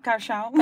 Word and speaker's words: Kaohsiung: Kaohsiung.